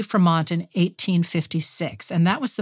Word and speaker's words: Fremont: John C. Fremont, it rhymes with Vermont.